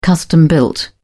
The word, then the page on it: custom built: British English